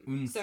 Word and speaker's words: Eun-se: Also, it's not, it's both syllables are a part of her name. – Eun-se.